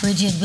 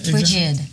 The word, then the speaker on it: Bridget: Bridget with a D on the end. Most people pronounce it with a T, but it's D on the end. – So how do you say that? – Bridget.